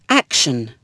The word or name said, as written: action